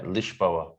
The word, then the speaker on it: Lisboa: Yeah, so they say, uh, what is it, Lishboa or some shit? Yeah, Lishboa. – And yeah.